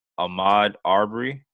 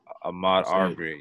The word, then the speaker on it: Ahmaud Arbery: I believe it's Ahmaud Arbery. Am I saying that right? Does anybody know? – I believe that's how you. – Ahmaud Arbery.